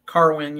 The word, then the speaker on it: Carwin: Carwin? Carwin? Is that how you pronounce it? – Carwin, yeah.